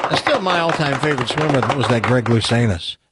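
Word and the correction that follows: Louganis: That guy that hit his head. – Luganus. Remember him? Hit his head on what's his name? – Luganus. – Ah, I've been mispronouncing his name.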